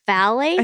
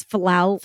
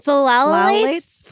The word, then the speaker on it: phthalates: That means that the product is formulated sans parabens, sulfites, SLS, and SLES, phthalates. – I think it's phthalates. – Phthalates.